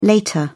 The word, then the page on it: later: British English